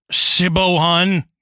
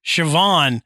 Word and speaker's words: Siobhan: I mean, if you've ever looked at the spelling of Siobhan. – Yeah. – And been like, oh, hi, Sibohan, you know, yeah. And then you go, oh, no, this is pronounced Siobhan.